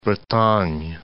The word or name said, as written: Bretagne